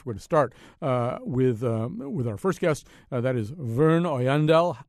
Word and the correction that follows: Oyanadel: It's actually pretty close. My full name in English is pronounced Werner, and my last name is Oyanadel. – Oyanadel.